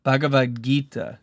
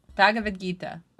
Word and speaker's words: Bhagavad Gita: Okay, how to pronounce Bhagavad Gita. – Oh, yep. – That's it. – Bhagavad Gita.